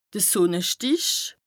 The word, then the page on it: de Sùnnestìch: Bas-Rhin; Strasbourg